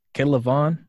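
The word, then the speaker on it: K'Lavon: How do you say his first name? Kaylavon? – Yeah.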